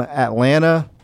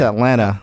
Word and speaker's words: Atlanta: Not a lot of people that are from Atlanta say Atlanta, because a lot of people put an emphasis on the T, but it's Atlanta. – Atlanta. – Atlanta. – Atlanta. – So it's like people put emphasis on the T hard sometimes, like Atlanta, which is like the broadcaster, you know, professional type of way of saying it, but it feels like most metro Atlantans say Atlanta.